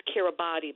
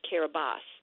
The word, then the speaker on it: Kiribati: It's spelled Kiribati, but it's pronounced Kiribati.